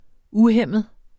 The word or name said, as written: uhæmmet